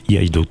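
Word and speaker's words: Iaido: Alors, du coup, votre école, du coup, c'est le Yeido. Je prononce bien ou pas ? – Yeido, tout à fait.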